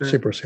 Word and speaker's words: Tsipras: So what would you have liked Tsipras, am I pronouncing his name right, the prime minister? – Tsipras, yeah.